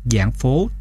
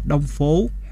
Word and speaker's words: Giảng Phố: Giảng Phố, tức là Giảng Phố Trại, âm lai mấy tiếng Campuchia vì viết chữ Đông và chữ Giảng hơi giống nhau, nên lắm nơi đọc là Đông Phố, hóa ra là vô nghĩa.